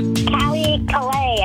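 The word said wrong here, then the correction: Colbie Caillat: I think how you say your name. – Not quite. – Colby Kalei. – That's right.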